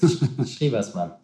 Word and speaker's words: Schriewersmann: You need to give us the right version. – No problem. It's Schriewersmann.